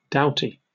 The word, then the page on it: doughty: British English